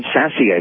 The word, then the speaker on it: Sasse: Ben Sasse, I believe, is the way he pronounces his name, at 6.